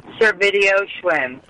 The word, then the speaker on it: Servidio-Schwinn: Elena, say your last names for me because I fuck it up. – Servidio-Schwinn. – All right. Elena Servidio-Schwinn. – Yeah, okay. – Yes. – I said it right. – It sounds like a video store on a bicycle.